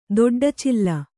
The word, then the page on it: ದೊಡ್ಡಚಿಲ್ಲ: doḍḍa cilla